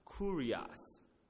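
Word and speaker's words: kurios (κύριος): So what the Greek word right here for Lord is pronounced kurios. It's spelled K-U-R-I-O-S, okay?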